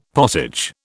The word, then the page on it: pottage: British English